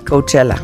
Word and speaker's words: Coachella: Coachella. – Coachella. Coachella. Y aquí, raya. Aunque ir a festivales es la pesadilla de la segunda. Raya, punto. Ahora, segunda opción. Rebeca y Marta hablaron sobre Coachella, raya, aunque ir a festivales es la pesadilla de la segunda, punto, raya.